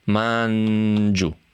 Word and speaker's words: Mangiù: Mangiù, con l'accento sulla U.